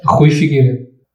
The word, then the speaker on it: Rui Figueiredo: Rui Figueiredo.